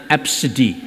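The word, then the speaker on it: ABCDE: Nah, sebetulnya bisa dipronounce ya. Bagaimana manggilnya? Absidi.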